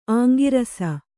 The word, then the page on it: ಅಂಗರಸ: kannada